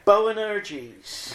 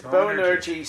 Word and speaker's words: Boanerges: Boanerges. – There we go. I was afraid to pronounce it. – Boanerges, sons of thunder.